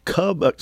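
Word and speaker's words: Kubb: From Blujour, we've got several mini cube PCs. Yeah, it's spelled that it looks like cub. It's K-U-B-B. – And I know it's cube, but I still say cub.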